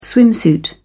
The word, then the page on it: swimsuit: British English